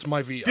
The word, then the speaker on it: Maivia: Now, is it pronounced Peter Maivia or Peter Maiva? – I've heard it's Maivia. – Billy Graham used to say Maiva, but what happened was Billy Graham is a story for another podcast.